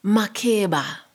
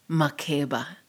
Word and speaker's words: Makeba: Makeba, Gogo says. Just three syllables. The ones I have only ever heard from her. Makeba.